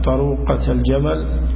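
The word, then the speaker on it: طروقتا الجمل: فإذا بلغت 76 إلى 90 ففيها بنت لبون، فإذا بلغت 91 إلى 20 أو 100 ففيها حقتان طروقة الجمل. الألف تكتب ولا تنطق طروقة الجمل، لأن الهمزة همزة وصل فلا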